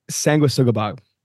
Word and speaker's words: Sanguisugabog: Did I get it right? – No. – But how do you say it? How do you say it? – Sansquigabog. – Sanguisugabog. – Sanguisugabog.